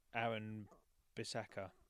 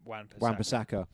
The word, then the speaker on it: Wan-Bissaka: Aaron Bissaka. – That's it, yeah. – Wan-Bissaka.